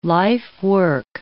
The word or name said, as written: lifework